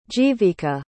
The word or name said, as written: jeevika